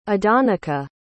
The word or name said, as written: adonica